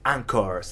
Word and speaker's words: Anchor: Eh, si vos estás ahí queriendo empezar un podcast, directamente te tenés que abrir una cuenta en Anchor, eh, que es como la película de Anchorman, se escribe así. – Anchor, se dice.